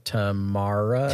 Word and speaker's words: Tamara: I mean, like, it's also with like Pacey and Tamara is how you pronounce her name.